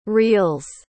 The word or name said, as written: reels